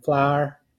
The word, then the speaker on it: flour: Or add some flour to cake batter, you know, flour. So that is how you could pronounce it, one syllable.